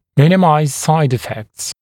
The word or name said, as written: minimize side effects